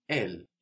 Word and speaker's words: elle: Elle. E-L-L-E. Elle. Good. So, this final E, which isn't usually pronounced, with some important exceptions, which we'll see, most of the time gives us a feminine noun.